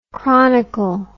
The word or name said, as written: chronical